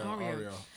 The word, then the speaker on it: Arya: Arya. Is that how you pronounce her name? – Yeah. – Arya.